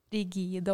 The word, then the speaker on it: rigide: Så har vi rigide organisasjoner. Uttaler jeg det riktig? Rigide. Ja.